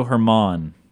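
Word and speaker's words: Germán: Yeah, the perfect game by Domingo Herman. – Herman, okay. So it's not German. Although it's spelled like German, but it is, yeah, it's spelled like German, not pronounced that way.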